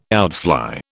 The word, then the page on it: outfly: British English